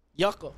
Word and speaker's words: Yuka: Is it yuca like the fruit? Like the vegetable? Yeah, the vegetable. Yuca. – Yuca. Yuca. – Y-U-K-A. – It's spelled different. It's Y-U-K-A. Yucca.